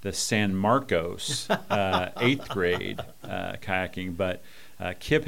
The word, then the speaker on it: San Marcos: the pronunciation is San Marcos.